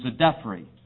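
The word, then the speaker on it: Djedefre: And that name, I understand you pronounce the DJ as a Z. So that would be Zedephri.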